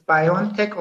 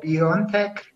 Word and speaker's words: BioNTech: You can pronounce it as BioNTech or BioNTech. It is, we did not dictate how the company should be pronounced.